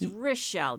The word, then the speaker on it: Rachelle: It's Rachelle. – Rachelle. – It may be pronounced spelled R-A, but it's pronounced yes. – R-I.